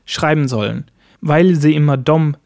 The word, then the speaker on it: de: De wird im Schwedischen sehr, sehr, sehr, fast 99% als dom ausgesprochen. In der Objektform, also sie oder ihnen, dann wird es dem geschrieben, aber auch noch dom gesprochen.